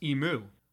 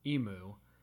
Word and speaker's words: emu: In America, it is pronounced emu, like the noise a cow makes, moo, emu. And in everywhere else in the entire world, and in Australia, which is where they're from, it's emu, which is more of like a meal.